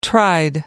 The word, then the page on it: tried: American English